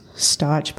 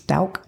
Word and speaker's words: Stauch: So it's spelt like Stauch. Like I thought it was Stauch, but it's Stauch is how it's pronounced.